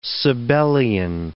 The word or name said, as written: Sabellian